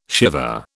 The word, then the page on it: Shiva: British English